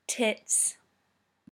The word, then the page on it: tits: British English